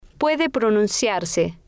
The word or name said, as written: puede pronunciarse